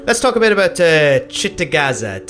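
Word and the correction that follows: Cittàgazze: Did I say it right this time? I'll never get it right. – Isn't it Gatze? – Chittagazzi. Chittagazzi. Thank you, guys.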